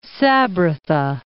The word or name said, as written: Sabratha